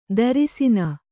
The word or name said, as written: ತ್ರಸ್ನು